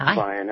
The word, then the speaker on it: Vian: It's Vian. That's fine. That's close enough. Just like Brian or Ryan.